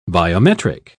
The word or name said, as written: biometric